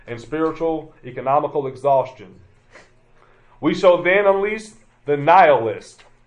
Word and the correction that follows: nihilist: No, nihilist. The word you're probably hearing in there is annihilate, but it's spelled like that.